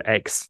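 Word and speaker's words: Aix: We got up, locked the house, and took the car to the city of X. A-I-X. I don't know how to pronounce it. – X on Provence, I think.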